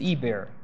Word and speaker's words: Eber: This is Eber. Eber, Eber. Okay? This ayin is not pronounced, but this little E is.